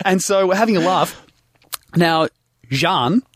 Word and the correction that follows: Sean: How do you say it? – Sean. – Sean. S-H. Okay, I've got it.